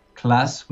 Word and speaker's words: Klaas: But my name is spelled K-L-A-A-S, class with double A with a K. Most people do the double S at the end instead of the double A, like classroom.